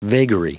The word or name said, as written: vagary